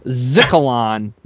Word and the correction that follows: Skylon: Am I saying that right, Steve? – Skylon. – Skylon, yeah, yep. – Skylon. Thank you, Randy.